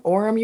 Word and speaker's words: Orem: Number one, I might not pronounce this correctly, so correct me if you know how to say this city properly. Orem, Utah? O-R-E-M, Orem, Utah? – Orem. Welcome, Orem.